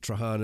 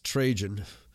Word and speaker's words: Trajan: The year after the death of Mithridates, the Roman Emperor Trajan, and I've been pronouncing it wrong, I've been pronouncing it Trajan, it's Trajan, broke the peace and began military hostilities.